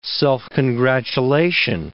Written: self-congratulation

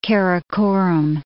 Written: Karakorum

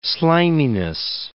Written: sliminess

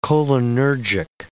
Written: cholinergic

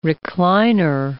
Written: recliner

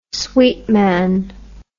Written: sweetman

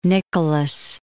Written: Nicholas

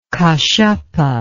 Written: kassapa

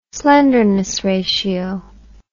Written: slenderness ratio

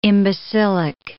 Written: imbecilic

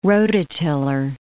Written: rototiller

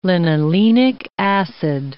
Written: linolenic acid

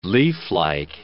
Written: leaflike

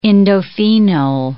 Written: indophenol

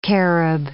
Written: carob

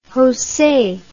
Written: jose

